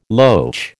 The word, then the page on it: loach: American English